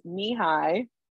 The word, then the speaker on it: Nehi: Yes, it's pronounced knee-high. It's flavored N-E-H-I.